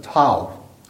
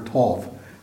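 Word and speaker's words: tav: Because, you know, the word for mark in the original is actually the same thing as the Hebrew letter, tau, or tov, depending on how you pronounce the Hebrew letters.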